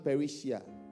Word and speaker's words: parresia: P-E, where's my spelling? P-E-S, it is P-A-R-R-P-A-R-R-E-S-I-A, but it is pronounced perisia. P-A-R-R-E-S-I-A.